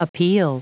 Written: appeal

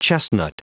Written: chestnut

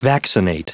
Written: vaccinate

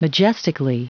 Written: majestically

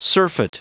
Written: surfeit

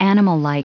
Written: animallike

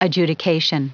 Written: adjudication

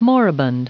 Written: moribund

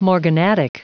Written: morganatic

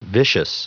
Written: vicious

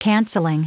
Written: cancelling

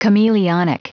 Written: chameleonic

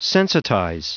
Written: sensitize

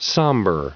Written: somber

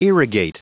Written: irrigate

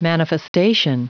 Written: manifestation

